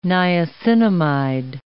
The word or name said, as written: niacinamide